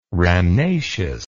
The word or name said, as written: rhamnaceous